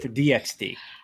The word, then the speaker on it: DxD: One episode of a show called High School D times D. I don't know if either of you have seen DXD. – Or heard of that. – It's normally DXD. Pronounced DXD.